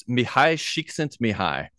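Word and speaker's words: Mihaly Csikszentmihalyi: I'm going to say his name and then you correct me. Okay. Mali Csikszentmihalyi. – Yeah, I've been told it's Mihai Csikszentmihalyi.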